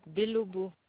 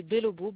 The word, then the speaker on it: Belobo: Oui, alors, mon nom, Belobo Amado. – Belobo, comme ça s'entend. Hein. – Belobo. Oui, Belobo, B-E-L-O-B-O, Belobo. – Okay. – Amado, plus loin, A-M-A-D-O, comme ça se prononce aussi.